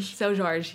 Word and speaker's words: Seu Jorge: I'm obsessed with, I'm going to fuck up the pronunciation, but Seu Jorge. – Seu Jorge, yeah.